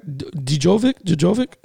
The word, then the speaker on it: Djokovic: Novak Djokovic, am I saying that right? He got disqualified. You're saying it right.